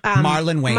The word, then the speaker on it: Marlon Wayans: Myron Waylon's. Yeah. Am I saying his name right now? The Waylon brothers. You know, Marlon Wayans.